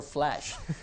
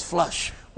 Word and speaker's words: flush: I'm afraid I speak the word flash. I always flush.